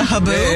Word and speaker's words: haboob: I'm still stuck on a haboob. – Yep, that's how you say it. – A haboob? – Yeah, yeah.